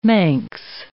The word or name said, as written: Manx